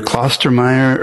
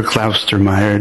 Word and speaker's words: Klaustermeier: It's either Klostermeyer or Klaustermeier, depending on where you're at.